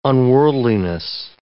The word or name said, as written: unworldliness